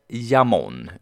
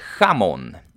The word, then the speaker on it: jamón: Men det uttalas inte jamón, utan det uttalas jamón och inte chamón.